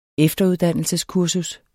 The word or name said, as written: efteruddannelseskursus